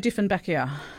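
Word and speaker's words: Dieffenbachia: But syngoniums, anthuriums, and arum lilies, and spathyphyllums, and how do you say Diffenbachia? – You got it right.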